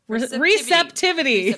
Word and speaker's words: receptivity: Left pointed cards may indicate hesitation or res- respite restivity? – Nope. – Recip- Receptivity. Receptivity. Receptivity. – Okay, there we go. Got that pronunciation.